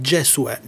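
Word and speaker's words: Gesuè: Certo, basta scrivere su YouTube, anche su Google, Occhi Stanchi, Gesue, però la cosa, ecco, l'attenzione è scrivere Gesue con la E, perché il mio nome è vero, che poi diventa anche nome d'arte, Gesue, G-E-S-U-E con l'accento.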